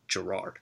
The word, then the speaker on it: Gerrard: who I believe this week said he wants his name pronounced with the emphasis on the second part. So we are going to call him from now on Gerrard.